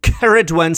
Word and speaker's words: Ceridwen: The standard version would be Ceridwen, C-E-R-I-D-W-E-N, Ceridwen. And it's also spelled with a double R occasionally as well, C-E-R-R-I-D-W-E-N.